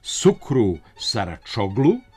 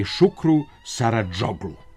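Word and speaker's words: Şükrü Saracoğlu: Stadion turskog fudbalskog kluba Fenerbahče zove se Šukru Saradžoglu, a u transkribovanju se ne treba oslanjati na izgovor, nego na pravila prenošenja određenih glasova. Tursko S sa donjim znakom, sličnim francuskoj sedilji, prenosi se kao Š, C kao Đ, a C sa donjim znakom kao Č.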